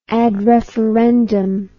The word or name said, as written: ad referendum